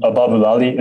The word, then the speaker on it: Ababulali: was uh, feelings and Ababu Ababulali. – Is that how you pronounce yeah. it? Yes, yes, that's it. That's it.